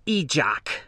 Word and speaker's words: Ejoq: "Hello, Ejoq," she said, reading my name off a file through her lenses and mispronouncing it. – "It's Ejoq," I corrected, hissing again. – She apologized with a smile like Kevin's and then said it correctly to show me she was listening.